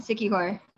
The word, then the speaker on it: Siquijor: Pangasinan and Siquijor. – Siquijor.